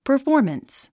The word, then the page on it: performance: pə r fɔ’ː r məns; パフォーマンス